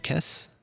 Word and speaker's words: Kes: In a similar vein, from episode 34, season 2, the doctor instructs Kes. Is that how you say it? Kes? – Yeah, that's right.